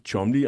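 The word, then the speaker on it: Cholmondeley: Øh, navnet staves på en helt anden måde, men det udtales Chomley.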